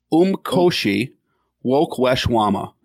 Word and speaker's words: Umkhosi Wokweshwama: It's the first one is three syllables. It's Umkhosi. And then it looks like woe is a syllable. Kwesh or kwe. And then shwama.